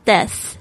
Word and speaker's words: death: 那么今天名词就是 death, 死这件事情。 D-E-A-T-H, 特别注意一下 TH 的发音。